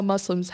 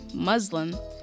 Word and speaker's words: Muslim: They'll be talking about how they use social media, how social media has affected their fashion style, and how Muslims have been portrayed in social media. – A note to our listeners. Though commonly mispronounced in the media as Muslim, the appropriate pronunciation of the term is Muslim.